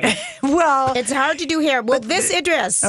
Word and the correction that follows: Iris: Okay, Idris. – Say her name. – Her name is Iris Apfel. – Thank you. Iris. She's a wrinkle-free 96-year-old Barbie because it's hard to do wrinkles in plastic.